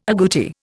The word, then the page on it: aguti: Indian